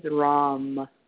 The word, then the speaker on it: Ram: And the sound for that chakra is Ram, with an R, R-A-M. Sometimes it's spelled R-A-N-G, but it's Ram, okay, with an R.